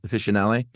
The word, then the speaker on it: officinale: Officinale.